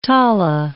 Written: tala

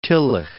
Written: Tillich

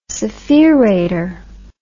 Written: spherator

